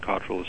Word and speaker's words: Cottrell: And Cottrell is spelled C-O-T-T-R-E-L-L.